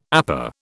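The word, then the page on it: épée: British English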